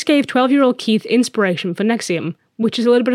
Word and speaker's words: NXIVM: It's not spelled like that. N-X-I-V-M, but it's pronounced Nexium.